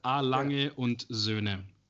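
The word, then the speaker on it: A. Lange & Söhne: So what about A. Langen's son? , No, you're getting really close, actually. It's A. Lange und Söhne.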